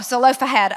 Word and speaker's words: Zelophehad: Michelle talked about the daughters of Zelophehad. Did I say it right? Oh, Zelophehad. Okay, that's so good.